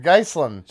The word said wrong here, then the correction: Ghislaine: Ghislaine. Ghislaine. – Yeah. I kept slaughtering that all all all weekend.